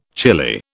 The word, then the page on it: chilli: British English